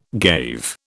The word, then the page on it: gave: British English